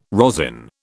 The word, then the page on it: rosin: British English